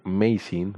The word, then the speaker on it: Mazin: Lionsgate anuncia que Eli Roth, director de Hostal, dirigirá una película basada en el exitoso videojuego Borderlands con guión de Craig Mazin. ¿Lo dije bien, Macho? – Sí.